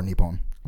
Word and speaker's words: Nippon: Japan is pronounced Nihon or Nippon.